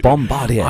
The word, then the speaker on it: Bombardier: Matt normally says Bombardier. Yes. It's Bombardier.